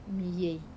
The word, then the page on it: mei: Wamesa